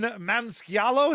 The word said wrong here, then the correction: Maniscalco: is that how you say it? – Jesus Christopher Cross. – I mean, how do you say it? Help him out. Maybe he'll send you tickets. – Maniscalco. Gotcha. I'm fucking it up. Your mispronunciation was so bad that mine has permanently been altered.